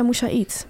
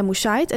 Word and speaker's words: Moussaid: Want iedereen zegt Nadia Moussaid. Maar het is dus Nadia Moussaid.